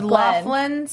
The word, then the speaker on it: Lachlan: It's Laughlin, spelled like Lachlan.